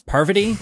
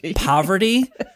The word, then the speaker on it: Parvati: Speaking of Sheree being not so bright, Kevin also still doesn't know how to pronounce Parvati correctly. He keeps saying poverty. Like, we live in poverty.